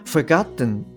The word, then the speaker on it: forgotten: あの forgotten っていうような発音してましたけど、 forgotten でも OK です。あの、これ t は、まあ、言いにくいので落ちちゃうケースっていうのも結構あるので、はい、あの forgotten でも OK です。